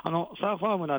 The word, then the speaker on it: SURFARM: ということでですね、今日は東辻ヶ丘にありますサーファーム新井さんの農場から中継でお伝えをしています。サーファームなんですけども、シールが貼ってあるんですけども、サーファームはカタカナではなくてですね、アルファベットになりますので、こちら SURFARM と書いてサーファームとなっております。